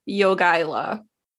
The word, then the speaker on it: Jogaila: Okay, so they're negotiating with the Grand Duke of Lithuania, who is named Jogaila.